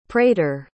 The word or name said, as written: prater